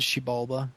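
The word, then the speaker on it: Xibalba: That's the Gateway Shadow Realm with begins with the X. – Shibalba. – That's what I was thinking. – Instead of a Z. Yeah, like you would see in xylophone, the X pronounces Z. It's actually pronounced as a she.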